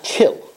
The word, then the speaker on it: kiln: Now, I didn't check it yet, but I've been told that the way to pronounce the word K-I-L-N is, believe it or not, kill. Isn't that interesting? I always thought it was either kiln or the L is silent, but they tell me that the N is silent.